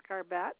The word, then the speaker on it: Garbett: Yes, it's Garbett, 13778. – Can you spell that name for me? – G-A-R-B-E-T-T.